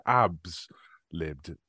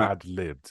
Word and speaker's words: ad-libbed: how did i say that word i said abs libbed which is not how that word is no. ad-libbed